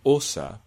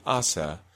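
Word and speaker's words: Åse: And that's pronounced osa, but most people pronounce it asa. It's sometimes transcribed as a double A, but it still represents a rounded O sound.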